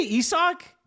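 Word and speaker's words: Isaac: Did you say Isak? – Yeah. Yeah, that's how it's pronounced.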